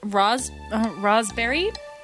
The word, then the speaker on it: raspberry: So it is pronounced like raspberry. So the S is sort of a Z.